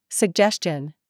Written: CHURCH

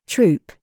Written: GOOSE

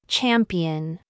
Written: mum

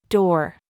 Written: DAD

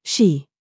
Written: SHOE

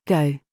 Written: GIG

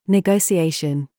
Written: GOAT

